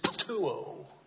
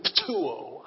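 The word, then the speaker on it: ptuo (πτύω): It is ptuo. Is that not cool or what? If you want to spell it in English, it's P-T-O-U, ptuo.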